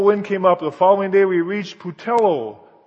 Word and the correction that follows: Puteoli: How do I say that, Rosaria? What? Puteoli. Okay. Thank you.